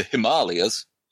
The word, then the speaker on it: Himalayas: And it chronicles the adventures of a World War II fighter pilot, Warren Williams, who is shot down over, and I believe the modern pronunciation is the Himalayas, during a dogfight with a German pilot.